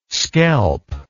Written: skelp

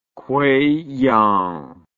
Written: kueiyang

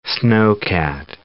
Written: snowcat

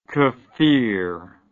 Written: Kfir

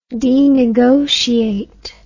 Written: denegotiate

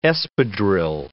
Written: espadrille

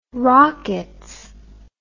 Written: Rockettes

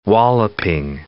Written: walloping

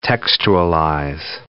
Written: textualize

